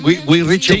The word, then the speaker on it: Guy Ritchie: Lo he dicho bien, Guy Ritchie.